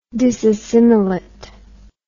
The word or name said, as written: disassimilate